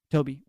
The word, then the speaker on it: Toby: I either go by Tobe or Toby. Either one's fine.